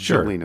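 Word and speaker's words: Gjelina: Gugelina, pronounced Gugelina. Sure. So it's a silent G.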